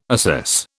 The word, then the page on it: assess: British English